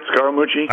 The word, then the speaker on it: Scaramucci: No, I think the hiring of Scaramucci, am I saying it right, Scaramucci? – I think you are.